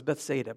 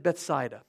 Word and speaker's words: Bethsaida: I always thought it was Bethsaida, but they said Bethsaida. So, since Diane concurs, and the Israelis called it that, we'll have to assume that that's the correct pronunciation.